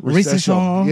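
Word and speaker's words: Risacher: However, they decide to go with, how do you say, Zachary, how do you say his last name? – Zachary Recession? – Recession. Yeah, there you go.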